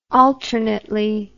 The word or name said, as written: alternately